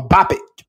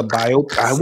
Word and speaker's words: biopic: no, I say a biopic. That's still kind of wrong though, because it's a biopic. What is it? I say biopic.